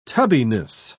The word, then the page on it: tubbiness: British English